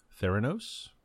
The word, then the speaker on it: Theranos: how do you pronounce the name of this company? – Theranos.